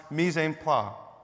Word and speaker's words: mise en place: I want to draw our attention to a little phrase here, mise en place, but I think it's French and it's pronounced mise en place.